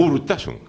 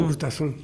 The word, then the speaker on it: Urtasun: Urtasun, con acento en la U. – Urtasun.